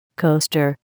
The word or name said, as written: coaster